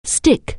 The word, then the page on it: stick: anglais